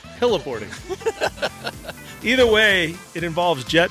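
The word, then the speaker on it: heli-boarding: Hellaboarding. Yeah. Not like hella, H-E-L-L-A, hyphen boarding, but hella as in helicopter, snowboarding.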